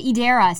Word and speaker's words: Edera: Andrew from Edera, which he pronounced it Edera, so I'm like, okay, that's how we pronounce it now.